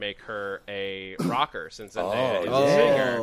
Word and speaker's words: Zendaya: The chat wants you to know that it's Zendaya. – Oh, thank you. I've said it wrong for a long time. Zendaya, really.